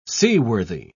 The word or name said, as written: seaworthy